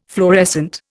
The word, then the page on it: fluorescent: Indian